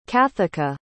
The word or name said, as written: cathica